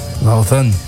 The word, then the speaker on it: Lausanne: Lausanne.